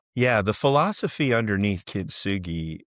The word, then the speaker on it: kintsugi: Is it kintsugi? Is that how you pronounce it? – Yeah. Yeah. – So kintsugi.